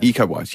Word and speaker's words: Iko Uwais: Iko Uwais, yes, that's it. I can never pronounce his name.